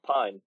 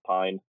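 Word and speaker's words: Pijn: but the Pin collaboration? – Oh, Pine. Yeah, Pine. I'm fucking way off. – But yeah, they get called Pigeon a lot. – Pigeon. – But no, officially it's not Danish, it's Dutch is the word I'm looking for. Yeah, it's pronounced Pine.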